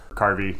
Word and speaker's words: karvi: These are like raiding vessels, and could even go into rivers and such, are known as Kravi. – Kravi, will you spell that? – K-R-A-V-E or K-A-R-V-I. Carvi. – Carvi.